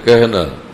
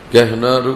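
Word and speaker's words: কেহনা (کہنا): কেহনার উচ্চারণ এটা না জানার কারণে গোফতান কাহনা কি হবে, এটা কাহনা না কেহনা,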